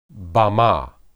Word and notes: bamah: hebrew

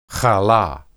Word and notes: halah: Hebrew

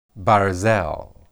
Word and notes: barzel: hebrew

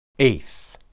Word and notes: eth: Hebrew

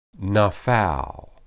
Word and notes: naphal: Hebrew

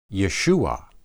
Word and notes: yeshuah: hebrew

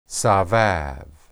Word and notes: sabab: hebrew

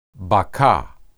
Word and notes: bakah: hebrew

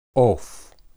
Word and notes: oph: hebrew

